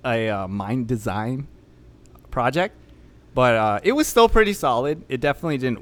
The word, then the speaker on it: MNDSGN: I listened to Rare Pleasure by, I don't know how to say the name. It's like Minsk. It's like MNDSGN. – Oh, Mind Design. – The name? Mind Design.